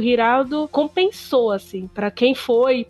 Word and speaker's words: Giraldo: E sem contar que ele ganhou uma semifinal do Santiago Girardo, que é outro que tá voltando pro tênis, o Santiago Giraldo, né? Desculpa, gente.